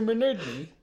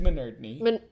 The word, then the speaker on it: Minardney: Richard Minutnut? – Mr. Minardney. Richard Minardney. – Richard Minardney. Mm-hmm. I'm so sorry. Have I been mispronouncing your name this entire time? – I didn't want to bring it up, but yes. I mean, like, it was fine. Like, I knew who you were talking to, but right. – the traditional pronunciation is Minardney, actually.